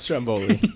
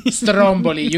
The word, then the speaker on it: Stromboli: It's pronounced Stromboli. – Stromboli.